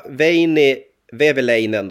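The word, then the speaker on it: Veini Vehviläinen: Som målvakt så tog jag Veini Väveläinen då. Var det rätt uttal, Abris, eller? – Ja, det lät jättebra.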